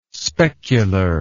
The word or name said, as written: specular